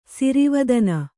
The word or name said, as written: ಸಿರಿವದನ